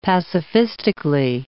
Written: pacifistically

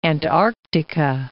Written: Antarctica

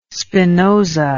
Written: spinoza

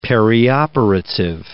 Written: perioperative

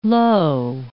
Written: low

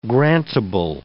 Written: grantable

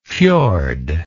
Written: fiord

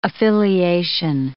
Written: affiliation

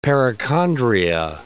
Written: perichondria

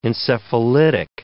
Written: encephalitic

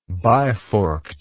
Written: biforked

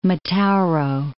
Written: Metauro

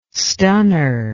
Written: stunner

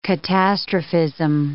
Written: catastrophism

